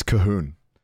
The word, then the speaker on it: Colquhoun: It's Cahoon. But like, it's got like loads of letters that aren't pronounced. It's like C-O-L-Q-U-H-O-U-N.